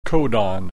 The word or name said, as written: codon